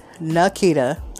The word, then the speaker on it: Nakita: So first name, Nakita. That's N-A-K-I-T-A. The next is Black, B-L-A-C-C.